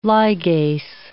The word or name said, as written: ligase